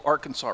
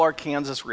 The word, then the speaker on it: Arkansas: Now, in every other state, it's pronounced the Little Arkansas River, but not in Kansas. There we called it the Little Arkansas River.